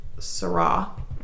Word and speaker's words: Seurat: So, for a little background, Seurat, silent T, for those trying to spell it.